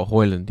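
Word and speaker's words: Højlund: And how did you pronounce his name? Haaland, Hart. The striker. – Oh, Hoyland, yeah. Rasmus Hoyland, that's the one.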